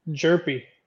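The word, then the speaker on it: Hjerpe: Cooper Herpy? – Jerpy. – Chirpy? – Jerpy. The H Jerpy?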